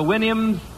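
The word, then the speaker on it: Houyhnhnms: Remember the Winims? Supposed to pronounce it like you do a horse's whinny, I think.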